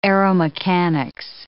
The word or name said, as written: aeromechanics